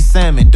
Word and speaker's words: salmon: Big fish, sauteed salmon, don't pronounce the L.